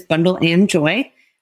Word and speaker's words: Bundle & Joy: So it's spelled Bundle X Joy. I say it is Bundle and Joy.